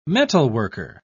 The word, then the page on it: metalworker: British English